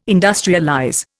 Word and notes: industrialize: Indian

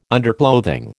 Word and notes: underclothing: American English

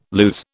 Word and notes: lose: British English

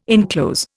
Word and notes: inclose: Indian

